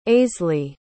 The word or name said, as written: aisly